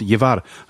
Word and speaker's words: llevar: Und das gibt's bei total vielen Worten, also insbesondere im Spanischen gibt es so eine Buchstabenkombination LJ zum Mitnehmen, para llevar. Heißt das. Und so, wenn du das liest und aussprechen willst beim Lesen, wirst du immer über dieses LJ stolpern.